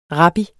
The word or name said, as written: rabbi